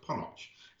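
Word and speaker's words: Ponoc: You may see it as Ponoch, Pete, and you may go, you're pronouncing that wrong. But in the preview screen I saw, there was an interview with the studio itself who said it's pronounced Ponoch. So I'm not having you correct me on this one. It's Studio Ponoch.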